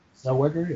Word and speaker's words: Zellweger: So I know Renee Zellweger. Did I say it right? – Zellweger, yeah.